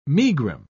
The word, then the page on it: megrim: British English